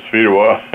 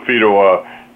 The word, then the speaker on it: Fedewa: And Twitter is Lawrence Fedewa at Lawrence Fedewa. I'm not too hard to find. If there aren't too many people with the name of Fedewa that come up.